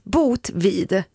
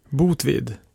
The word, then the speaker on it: Botvid: Alltså, Botvid. – Botvid. – B-O-T-V-I-D.